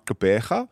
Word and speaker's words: Gqeberha: Actually, Eastern Cape, PE, now Gqeberha.